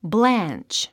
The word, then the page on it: blanch: blǽntʃ; ブランチ